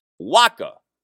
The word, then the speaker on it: WACO: How about W-A-C-O, pronounced Waka?